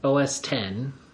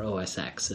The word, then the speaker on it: OS X: So, OS 10, or OS X, as it's commonly mispronounced.